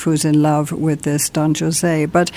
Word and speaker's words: Don José: You asked why I mispronounced Don Jose. It's into a cultural thing here. Bizet is French. And the opera is in French. – And the opera is in French, not in Spanish. – That's why he's called Don José.